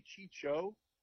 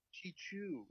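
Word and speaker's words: Chouinard: Did you just say Chicho? – Is that how you say it? – Chichu. Chichu. The Chichu train.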